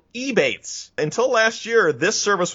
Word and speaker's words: Ebates: It rhymes with rebates, which is kind of like what you're getting. Rebates, Ebates, great.